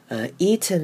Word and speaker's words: eaten: 好，接下来是看 eaten 这个字。 Eaten 呢，后面的 T-E-N 呢，我会念成嗯，把 T 都变不见喽，所以我们会念成 eaten。有没有发现 T 也没有发出来，N 的声音也没有，单纯用一个嗯的声音念过去，就会比较像老美讲话的方式，会非常非常的顺哦。